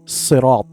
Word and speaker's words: الصراط: Correction, ensure correct pronunciation of sirat, sirata, as sad, should be pronounced with a heavy sound.